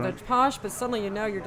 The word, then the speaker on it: pox: It's actually spelled P-O-X, Posh.